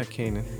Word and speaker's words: Kanan: We're gonna play the hip hop songs Kanan, is that how you say? – Yeah, Kanan.